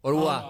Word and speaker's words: Au revoir: En francés, ¿cómo se dice? Arrebois, ¿no? – Au revoir.